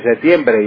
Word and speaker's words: septiembre: No, hay que pronunciar la P, que es bilabial, hay que juntar los labios para pronunciarla. Septiembre, septiembre, y no septiembre. Septiembre, septiembre con C equivale a una derivada de secta.